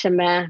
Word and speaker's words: Semaine: Because I'm thinking French, Semaine means week, or how do you pronounce it? – You are completely right, and you are saying it correctly. As Americans, we say Semaine.